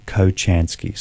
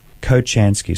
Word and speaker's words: Kochansky: They're not expensive, very affordable, and my name is Andrew Kochansky. So I'll spell my surname. So Andrew is straightforward. Kochansky, so I'll spell that. K-O-C-H-A-N for November, S-K-I. That name again, Kochansky, K-O-C-H. A-N for November, S-K-I.